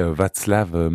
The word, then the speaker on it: Václav: Donc Hélène, au tout début, elle connaît d'abord Julie, mais puis elle connaît aussi un autre musicien qui est Václav Mazarik. – Václav.